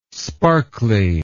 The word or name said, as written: sparkly